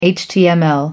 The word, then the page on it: html: American English